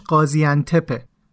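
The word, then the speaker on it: Gaziantep: توی قسمت اول من دو تا کلمه رو اشتباه تلفظ کردم اون شهری که موبین و دوستاش میرن اونجا که یه کمپ هم داشته اسمش قازیانتپه همینطور به اون ونهای توی ترکیه هم میگن دلموش که من اشتباه میگفتم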